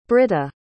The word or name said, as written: britta